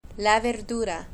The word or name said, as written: la verdura